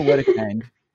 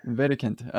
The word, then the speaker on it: Wedekind: Wedekind. Okay. – Yeah, it's very German. It's very German. – I should have known. I knew that German W's were pronounced like a V, but my bad.